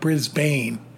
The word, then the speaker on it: Brisbane: I pronounced that correctly, folks. It's not Brisbane. Brisbane. – Brisbane, say it like you mean it.